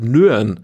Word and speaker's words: Nguyen: diesen James Nguyen, ich hoffe, ich habe es richtig ausgesprochen. – Heißt das wie Nürnberg, musst du es aussprechen, ohne Berg, Nürn.